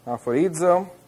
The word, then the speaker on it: aphoridzo: Aphorizo. It's spelled A-P-H-O-R-I-D-Z-O. This word here, or that letter there, is a D sound with a D and a Z together.